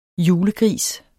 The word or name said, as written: julegris